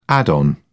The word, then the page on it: add-on: British English